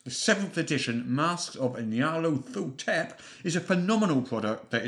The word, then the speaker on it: Nyarlathotep: How do you pronounce that word? – I pronounce it Nyarlathotep.